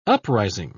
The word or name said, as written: uprising